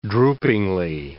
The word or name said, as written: droopingly